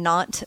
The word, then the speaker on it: Nantes: I was in Nantes, France, N-A-N-T-E-S. They, you know, drop the S at the end when you're pronouncing French.